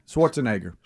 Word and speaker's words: Schwarzenegger: Yeah, and so Arnold, is it Schwarzenegger? – Schwarzenegger. – Schwarzenegger. Schwarzenegger. I've never been able to say that right. Yeah, that's it.